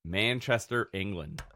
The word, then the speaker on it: Manchester: Manchester, England. – Emphasis on the man.